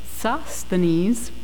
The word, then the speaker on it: Sosthenes: Sosthenes.